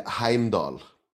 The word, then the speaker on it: Heimdal: So I've got it down as um, Hamidul. Have I got that quite, quite right? – Well, uh, it's more like Heimdal.